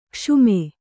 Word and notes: ಕ್ಷಮ: Kannada

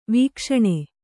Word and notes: ವೀಕ್ಷಣೆ: vīkṣaṇe

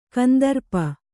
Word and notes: ಕಂದರ್ಪ: kandarpa

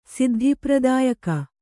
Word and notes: ಸಿದ್ಧಿಪ್ರದಾಯಕಿ: siddhi pradāyaki

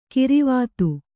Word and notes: ಗೊರವಿತಿ: Kannada

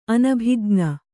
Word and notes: ಅನಭಿಜ್ಞ: kannada

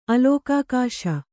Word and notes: ಅಲಿಕಾಕ್ಷ: Kannada

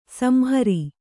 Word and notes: ಸಂಹಾರ: samhāra

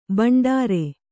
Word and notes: ಬಂಡಾರ: Kannada

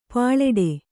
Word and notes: ಪ್ಲೇಟು: plēṭu; Kannada

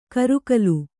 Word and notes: ಕರಿಗಾಲ: kaigāla

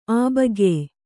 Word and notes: ಅಭಾಗ್ಯ: Kannada

